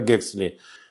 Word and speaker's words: Хаксли: Это и Томас Хаксли, иногда его фамилия произносится как Гексли, выдающийся биолог, защищавший теорию Дарвина так яростно, что его назвали бульдогом Дарвина.